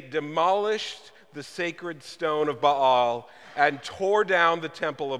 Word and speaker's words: Baal: Oftentimes it's mispronounced by us Americans as Baal. It's Baal, okay?